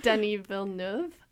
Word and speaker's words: Denis Villeneuve: Try to pronounce it. Denis Villeneuve? – Yeah. – Yeah, sounds good.